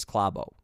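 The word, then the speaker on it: Klæbo: He says his name is Klabo. So that's what I'm saying it. Anyway, so if you were listening and you're like, this guy's not saying his name right. No, I am saying it right. I am saying it the way the Norwegians pronounce it.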